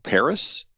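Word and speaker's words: PVRIS: a band called Paris, uh, and it's spelled P V instead of an A. It's P V R I S, and they they had a, a, a few years ago they released an album called White Noise, and I really liked it.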